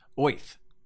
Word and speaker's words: Oerth: And he originally wanted to detail the entire planet, which he had called Earth, O-E-R-T-H. Do you pronounce it Earth? – O-Earth. – O-Earth. – Yeah. – Apparently, Gygax said it was actually pronounced Oith.